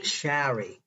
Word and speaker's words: Shari: Shari. – Okay. Remember, folks, it's Shari. I got to say it right. You have to really enunciate the A.